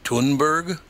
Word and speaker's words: Thunberg: Wouldn't her name be Thunberg? You wouldn't pronounce the H, would you? – That's probably right. – So Thunberg.